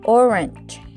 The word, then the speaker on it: orange: El color anaranjado se pronuncia orange. Orange. Es como si dijeras or primero. ¿Recuerdas la pronunciación que te dije que se coloca la lengua en el paladar? Er, er, así. Pero esta vez es con o. Entonces, or. Y luego viene como una e. Orange.